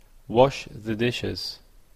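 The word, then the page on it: wash-the-dishes: Canadian English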